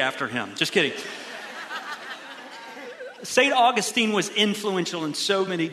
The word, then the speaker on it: Augustine: Now, if you're highly educated and a little pretentious, you might pronounce it Augustine, but we don't say Saint Augustine is the city where I live, so I say Augustine. Anyhow, potato, potato.